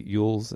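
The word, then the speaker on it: Elwes: Sadie Frost, Monica Bellucci, Carrie, the guy from Princess Bride, Carrie Ewells.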